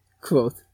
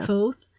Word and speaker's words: Kvothe: Kvothe. – Kvothe. – Yeah. Kvothe.